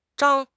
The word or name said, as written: Zhang1